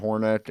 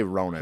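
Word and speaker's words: Hronek: And I know you're like excited about Hornick and like is that how you say it? – Hronik. – Hronik? – Yeah, not Hornick, but Hronik. – I'm just gonna say Hronik.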